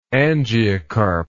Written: angiocarp

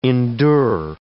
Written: endure

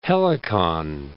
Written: Helicon

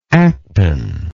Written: aketon

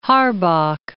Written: Harbach

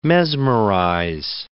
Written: mesmerize

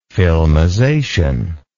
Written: filmization

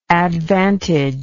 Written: advantaged

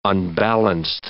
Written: unbalanced

